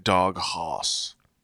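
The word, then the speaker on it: Dog Haus: Dog Haas. – Yeah, Dog Haas. It's spelled Dog, H-A-U-S.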